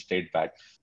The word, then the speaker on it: STR8BAT: Today we are going to talk about the Straight Bat, S-T-R-8-B-A-T, which is pronounced as Straight Bat.